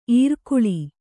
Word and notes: ಇರ್ಕುಳಿ: irkuḷi

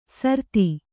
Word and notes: ಸಾರತಿ: Kannada

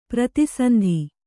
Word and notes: ಪ್ರತಿಸಂಧಿ: prati sandhi

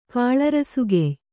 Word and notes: ಕಾಳರಸುಗೆಯ್: kannada

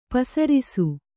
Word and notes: ಪೆಸರಿಸು: pesarisu

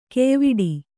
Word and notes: ಘಾಯವಡೆ: ghāyavaḍe

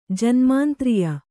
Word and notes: ಜನ್ಮಾಂತ್ರಿಯ: Kannada

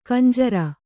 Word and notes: ಖಂಜರಿ: khanjari

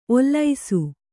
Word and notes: ಓಲಿಸು: Kannada

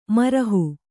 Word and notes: ಮರಹು: marahu

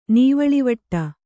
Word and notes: ನೀವಳಿವಟ್ಟ: kannada

